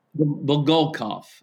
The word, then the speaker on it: Bulgakov: But Bulgakov is closer. I mean, it's like the next to last syllable is what's usually emphasized in Russian.